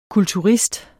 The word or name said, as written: kulturist